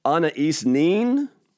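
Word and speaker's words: Anaïs Nin: Her name is, it's spelled really strange, but it's pronounced Anais Nin. It's actually spelled A-N-A-I-S, and her last name is N-I-N.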